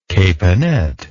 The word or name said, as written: caponette